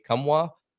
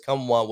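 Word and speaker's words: Nkamhoua: It's Olivier Kumwa, if I'm not mistaken, is how you pronounce his last name.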